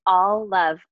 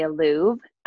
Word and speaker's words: Alluv: It's All Love Place. A-L-L-U-V Place. It looks like a lot of people want to say a Louvre. So it sounds very French and sophisticated, but it's all love.